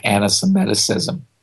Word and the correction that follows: anti-Semitism: Anti-Semiticism? – Yeah. – Anti-Semiticism? Is that what you... – Yeah. – I thought there was a new person in town. Anti-Semiticism. – Anti-Semitism? Semitism, yes.